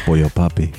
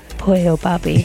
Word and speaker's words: Pollo Papi: If you're interested in purchasing my brand new merch, Pollo Papi. But you got to say it like that. – Pollo Papi.